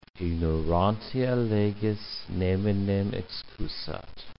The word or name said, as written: ignorantia legis neminem excusat